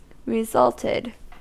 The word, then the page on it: resulted: American English